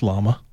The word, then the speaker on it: Llama: But the language model for Meta will be called Llama, or Llama, L-L-A-M-A. How would you pronounce that? Hmm. I would say that's Llama. – I'd go with Llama. – But so it's L-L-A-M-A because there is a race to implement AI in more tools.